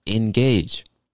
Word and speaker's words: engage: Now, one other interesting exception is engage. Two syllables, but it makes the A sound.